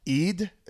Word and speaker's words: Eid: Eid. It's called it's spelled EID, but that is like a couple months before.